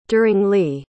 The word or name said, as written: duringly